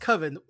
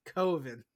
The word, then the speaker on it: Coven: No, it's pronounced Coven. No. That's like no. It's Coven. – It's like, that's like oven. It's Coven.